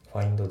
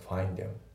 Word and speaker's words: find: であと発音なんだけど、 find の D がねほぼ発音されてない、抜けてますね。 find them、 find them じゃなくて find them ですね。